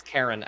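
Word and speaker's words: Charon: I think it's Karen actually is how to pronounce it, but yeah, because Chiron is like a hand.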